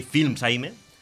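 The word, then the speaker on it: Fils-Aimé: ¿Reggie Films Aime o Aime? No lo sé, seguro. – A lo mejor es francés. ¿Reggie Films Baguette? – ¿Sabes qué pasa? Que como sus padres son haitianos, oh, la. – ¿Vale? Se pronunciaría en francés, realmente.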